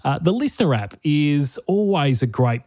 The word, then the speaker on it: LiSTNR: Listener, L-I-S-T-N-R is how you spell it.